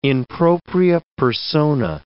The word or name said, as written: in propria persona